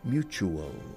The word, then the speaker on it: mutual: In English, mutuo se pronuncia mutu, chu, chufla, chu, mutual.